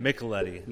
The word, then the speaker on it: Micheletti: Micheletti.